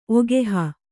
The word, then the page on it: ಒಗೆಹ: kannada